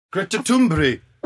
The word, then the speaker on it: Greta Thunberg: My producer literally sent me a link to a Swedish pronunciation. – Pronunciation, darling. – And I'm going to actually try and play it. Because it is a pronunciation. – Pronunciation! – The likes of which I have never, ever heard. Greta Thunberg. – We've all been pronouncing it wrong.